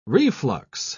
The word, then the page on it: reflux: British English